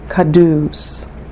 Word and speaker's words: Quddus: And for that reason, I'd like to emphasize this practice with the sacred word kadus. Kadus. It's spelled Q-U-D-D-U-S, kadus.